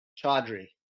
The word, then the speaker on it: Chaudhry: Chaudhry.